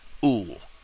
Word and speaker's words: Uhl: And citing Hans Gisevius, Uhl is the one who affirmed, Uhl, U-H-L is the way the name is spelled, Uhl is the one who affirmed, a little while before he was shot, that he had been designated to assassinate Hitler.